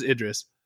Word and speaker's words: Idris: It is just Idris. That he's like, that's what his wife calls him. So from here on out, if you ever have a question about how to pronounce Idris Elba's name, it is Idris.